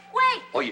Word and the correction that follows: wear: No se dice wey. Se dice wear.